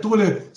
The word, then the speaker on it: Thurler: Thurler se escreve T-H-U-R-L-E-R,